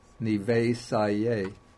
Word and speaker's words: Nivesaye: So what happens is that this E has to be expanded into AY, and then you put the E at the end of Nivesaye.